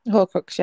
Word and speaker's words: Horcrux: I do think that this is with the whole thing of is it the Horcrux? Is that how you say it? – Horcrux, yeah.